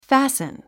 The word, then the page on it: fasten: fǽsn; ファスン